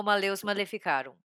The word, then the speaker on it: Malleus Maleficarum: Olha, eu não falo latim também, então é no no achômetro, mas eu pronuncio como Maleus Maleficarum.